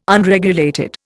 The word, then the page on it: Unregulated: Indian